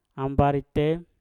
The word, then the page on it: ambari te: mpur